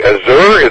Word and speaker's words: Azure: Okay, also I want to inform everybody I got into, probably not going to say this right, Azure, is that the way it's pronounced? Azure's name, yeah.